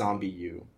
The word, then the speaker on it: ZombiU: But how did exactly, like, did they ever actually pronounce it? Because it looks like it's Zombiu. So is that like Zombu? Zombiu? – Zombiu. – Zombu. – Zombiu. I think it's Zombiu.